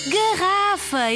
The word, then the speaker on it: garrafa: Garrafa é a resposta certa. Verde garrafa. Garrafa. Três sílabas. A sílaba tônica é ra.